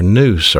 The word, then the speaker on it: nous: And the mind is pronounced N-O-U-S in the Greek New Testament, the nous. And you also have a heart, and that's pronounced cardia, K-A-R-D-I-A, in the Greek New Testament.